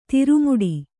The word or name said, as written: ದ್ರಾಮಿಡಿ